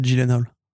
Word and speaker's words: Gyllenhaal: je vais pas t'emmerder, mais je crois qu'on dit, je crois vraiment qu'on dit Gyllenhaal.